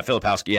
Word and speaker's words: Filipowski: Is it, it's Filipowski, right? – Yeah. Filipowski. Yeah.